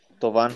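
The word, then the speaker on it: Thauvin: ¿Se pronuncia Tobin? ¿Tobin? Florian. – Tobán. – Tobán. Tobin, Tobán. Tobán. Sí.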